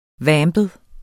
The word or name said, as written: vampet